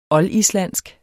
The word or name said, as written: oldislandsk